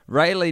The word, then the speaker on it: Raleigh: Raleigh, North Carolina is pronounced Ray, think ancient Egyptian sun god, Lee, think... Well, don't even worry about that part. You've already nailed it.